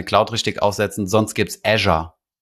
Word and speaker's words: Azure: Die haben doch dieses Azure oder wie spricht man das aus? – Azure, ist es wirklich, sagt man wirklich Azure. – Ja, ein Azure.